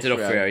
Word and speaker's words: D'Onofrio: D'Onofrio, is it? – Oh, yeah, D'Onofrio, yeah.